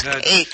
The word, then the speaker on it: Cadiz: C-A-D-I-Z. – Cadiz. – It's pronounced by the locals or natives. It's pronounced Cadiz.